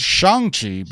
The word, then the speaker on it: Shang-Chi: Um, but also, it also shows that Wenwu, the character played by Tony Leung, uh, and it, it's Shang-Chi, by the way.